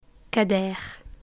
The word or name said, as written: cadeir